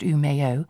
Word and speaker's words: Umeå: Umeå, pronounced Umeå, is famous in Sweden as the home of radical ideas.